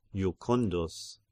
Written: Jucundus

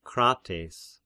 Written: Krates

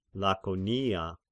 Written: Lakonia

